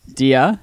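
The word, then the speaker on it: día: Diaz? – Dia? – Dia.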